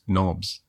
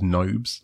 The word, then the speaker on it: knobs: And he was saying that the first time they took like Warhammer 40,000 to a trade show in the USA, the people that had been playing the game already didn't really know that orc knobs, like knobs were pronounced knobs. They thought it was nobs, like nobles. Apparently that was the thing. And it's like, no, it's knob.